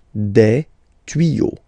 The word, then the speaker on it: tuyaux: Des tuyaux. Same rule. You don't pronounce it. Des tuyaux.